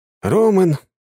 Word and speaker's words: Роман: «Роман! Роман!» — делая ударение на первый слог, подбежал водитель и сунул Роману телефон.